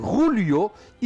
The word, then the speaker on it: Julio: On ne dit pas Julio parce qu'en espagnol, le J se prononce R. Donc, c'est Julio Iglesias.